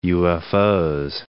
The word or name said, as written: UFOs